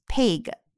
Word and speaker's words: pig: 那么有一个词就是 pig 这个词，大家注意不要念成 pig 或者读成 pig。那么如果读成 pig，就是发成了双元音 a；如果读成 pig 呢，就读成了长音 e。注意是介于 a 和 e 两者之间的发音，短音 a 要短促有力。